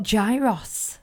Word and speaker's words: gyros: So, in the Greek booth, they've got gyros. What's gyros, gyros. Gyros, is that how you say it? – Yeah, it's flatbread with chicken that's usually chicken anyway, with I can't remember what the sauce is called now.